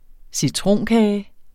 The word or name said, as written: citronkage